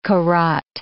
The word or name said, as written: Korat